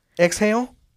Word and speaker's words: XIL: X-I-L. – Fuck. Little kiss. – Little kiss. G, little kiss. M, little kiss. – Exhale. XIL. That's how you say it.